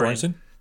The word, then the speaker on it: Göransson: Göransson? – Thank you.